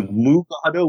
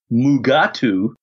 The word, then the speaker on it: Mugato: So it was changed to Mugato, which Kelley and William Shatner proceeded to pronounce Mugatu. Yeah.